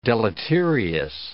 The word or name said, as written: deleterious